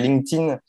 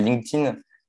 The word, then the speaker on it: LinkedIn: je dirais LinkedIn. Euh, il y en a qui vont dire LinkedIn parce qu'ils vont le prononcer à la française. Euh, il y en a qui vont dire LinkedIn. Mais euh, voilà, pour moi, c'est LinkedIn.